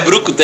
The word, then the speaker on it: brucutu: É, é brucutu. É, é um peixe isso? – Burucutu. Falei errado.